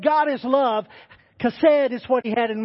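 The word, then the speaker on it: chesed (חֶסֶד): It's in Hebrew, it's chesed. I know it's H-E-S-E-D on the screen there, but it has a little deal under the H, which means it sounds like a K, C-H-A. Chesed.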